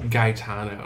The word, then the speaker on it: Gaetano: Gaetano. – Oh, really? Mm-hmm. Why did I think it was Catalano? – Because they kept pronouncing that as a very hard G in the episode.